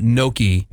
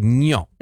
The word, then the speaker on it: gnocchi: It's not gnocchi. – Yeah. – Gnocchi. It's like gnocchi. – That's what the gnocchi. G is for. – Gnocchi. – Come over. – Yeah. Okay, wait, say it correctly one more time. – Gnocchi. – Gnocchi. – Gnocchi. – It's, yeah, close, sure. It's closer to gnocchi.